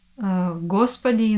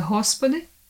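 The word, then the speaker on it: Hospody (Господи): letter G in Russian language is G, mm-hmm. and in Ukrainian it's H. Mm-hmm. So, for example, word Gospody in Russian, in Slavic language and in Ukrainian it's a Hospody.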